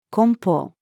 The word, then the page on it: 梱包: female